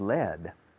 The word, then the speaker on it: led: Okay, the past tense of lead is led. Now, led, que se pronuncia igual que plomo, que se escribe lead, sin embargo se escribe led, L-E-D.